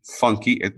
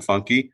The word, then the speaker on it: Funke: There's a documentary called F-U-N-K-E, Funky. He pronounces it Funky.